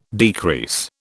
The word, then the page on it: decrease: British English